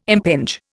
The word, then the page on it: impinge: Indian